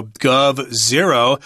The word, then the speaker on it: GovZero: So we've got a little G there, a zero. It looks like an O, but it's actually a zero. And then we've got V, so GovZero, and that's how it's pronounced.